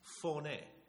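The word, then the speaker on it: Phoné (φωνή): the way it's written in Greek is P-H-O-N-E. Interesting, isn't it? Phoné. That's how you say it.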